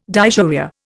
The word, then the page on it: dysuria: Indian